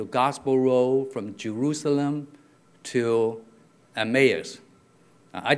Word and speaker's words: Emmaus: You know, so what they pronounce is Emmaus, okay? But if I go to the Chinese translation, usually that tells me how to read it, because the Chinese, they just translate phonetically. And it's almost like the local language, the original language you might pronounce is Emmaus.